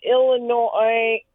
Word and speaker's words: Illinois: But then it is Illinoisans, and people in Illinois, and then the S is not pronounced.